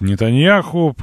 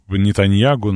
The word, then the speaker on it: Нетаньяху: Вот, Блинкен встретился с Нетаньяху, при... Нетаньягу, наверное, правильно говорить, да, при...